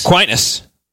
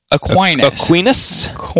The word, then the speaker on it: Aquinas: Aquinas. Shocker. Yeah. You know, huge twist. – How do you pronounce it? Aquinas.